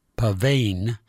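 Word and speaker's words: pavanne: He said, so that people wouldn't mispronounce it as pavane or pavane, he spelled it with the double N, so it should be pronounced pavane, as it is anyway.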